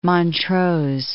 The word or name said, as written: Montrose